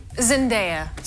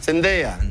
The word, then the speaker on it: Zendaya: Zendaya, ¿se dice Zendaya? No se dice Zendaya. ¿Tu nombre está bien dicho? – Zendaya. – Zendaya. – Yes. Vale, mejor.